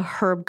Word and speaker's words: herb: Or as I like to call it, the herb garden. Pronounce the H, please. – I think that the British indeed pronounce the H in herbs.